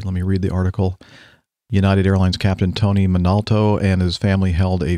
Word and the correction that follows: Montalto: Employees, did I mispronounce his name? Montalto. Okay, there we go.